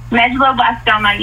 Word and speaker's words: medulloblastoma: Medulloblastoma, is that how you pronounce it? – Medulloblastoma, yes. It's, thank you.